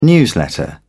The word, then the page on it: newsletter: British English